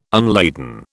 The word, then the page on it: unladen: British English